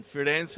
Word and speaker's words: Firenze: Am I pronouncing that right, Firenze? – That's right. Firenze, it's our premium brand, where we're using this brand to serve the U.S.